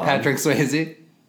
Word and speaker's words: Patrick Swayze: Patrick Swayze in Ghost.